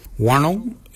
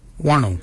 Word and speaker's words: wondil: Wonnil is spelled W-O-N-D-I-L, Wonnil. Your nail is your underarm. Nail is spelled N-G-A-R-L-Y, nail, for underarm.